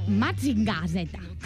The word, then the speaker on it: Mazinger Z: En japonès es pronuncia Mazinger Z.